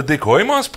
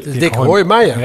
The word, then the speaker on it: Dik Hooimeijer: Organisator van de Dik Hooimeijer Prijs. – Nee, Dik Hooimeijer.